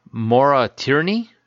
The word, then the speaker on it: Maura Tierney: Maura Tierney.